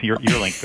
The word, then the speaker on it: Juurlink: First of all, am I saying your last name correctly? – Close enough, Juurlink, but I get it all in different ways.